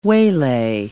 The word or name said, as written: waylay